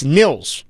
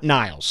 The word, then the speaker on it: Nils: It's Nils Hoglander, not Niles.